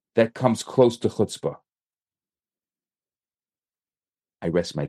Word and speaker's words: chutzpah: And chutzpah, you say chutzpah.